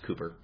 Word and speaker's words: Cowper: Now, for my part, I tend to believe that William Cooper, it's spelled Cowper, but it's pronounced Cooper, he was indeed a Christian and was delightfully surprised when he entered into the presence of God.